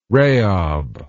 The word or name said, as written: raob